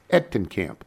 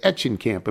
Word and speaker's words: Etchenkamp: My last name is pronounced Etchenkamp. You pronounced it at Etchenkamp in my first red email. Don't worry. You're not the first and certainly not the last to make this mistake. It's a German name.